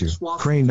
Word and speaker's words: Ukraine: Meanwhile, Seth, $3, it's pronounced you. Crane, not your Crane Dalton.